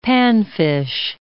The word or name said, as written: panfish